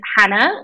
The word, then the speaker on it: Jana: Easiest place is Jana, which is spelled J-A-N-A. You wouldn't expect that, but I'm half Spanish, half English, and my parents made my name up. So thank you, Mum. Thank you, Dad. So it's Jana spelled J-A-N-A. Think jalapeno, okay? That's the Spanish H. Bartlett, B-I-M-B-A-R-T-L-E-T-T, Alonso.